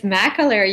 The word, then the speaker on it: McAleer: Is it McAleer? – It's McAleer. You're one of the only ones to get it right.